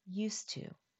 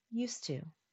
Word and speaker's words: used to: Although it is two words, used, spelled U-S-E-D, and to, spelled T-O, we drop the D sound and pronounce it like one word, used to. Practice with me, used to.